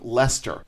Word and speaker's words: Leicester: And continuing from the same article, the town of Leicester, and you see, I can pronounce that because I'm from Massachusetts.